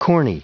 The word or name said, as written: corny